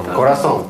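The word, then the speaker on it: corazón: Курасон. – Эль курасон, да? – Курасон. – Знак ударения почему? Потому что если бы его не было, то по правилу ударение ставилось бы на предпоследний слог. Эль курасон.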